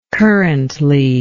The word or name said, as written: currently